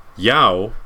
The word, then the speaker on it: Yeoh: Michelle Yao. – Y-E-O-H.